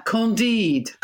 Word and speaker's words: Candide: Candide.